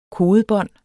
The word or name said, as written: kodebånd